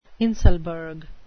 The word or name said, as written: inselberg